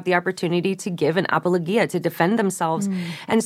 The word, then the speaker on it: apologia: And that word that Peter uses there for defense is the Greek word, and I'm going to butcher the pronunciation here, but it's apologia. And That's pretty good.